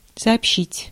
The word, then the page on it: rapporter: [ʁa.pɔʁ.te]; France